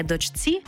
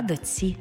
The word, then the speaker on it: дочці: Пишемо «чці», а вимовляємо «ці». Наприклад, не «дочці», а «доці».